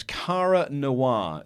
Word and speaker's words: Cara Noir: So I'll say it again. It was Cara Noir. C-A-R-A Noir. N-O-I-R.